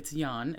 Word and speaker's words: Jan: Yeah, it's spelled Jan, but it's Jan, and it's like the Dutch John.